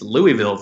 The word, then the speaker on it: Louisville: It's pronounced Louisville. – Louisville, my apologies. I'm from Boston, Louisville, so unfortunately. – Yeah. If you're really from there, if you really want people to know that you're an ally, you cut out the middle syllable and you say Louisville.